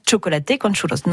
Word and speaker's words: chocolate con churros: Le, je le, enfin, je le prononce comme je peux, hein, mais on dirait non, donc ça, se prononce vraiment chocolaté con churros, non chocolate ou chocolat.